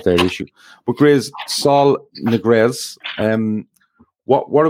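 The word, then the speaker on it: Ñíguez: Niguez.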